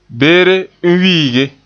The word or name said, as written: wiige